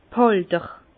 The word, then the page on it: d' Hälter: Bas-Rhin; Herrlisheim